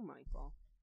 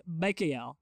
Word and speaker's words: Mikael: Also, no, but like, really, apparently, me and Andrea found out, it's Mikael, if you want to talk about St. Michael. – It's the original pronunciation. But you can still call him Michael.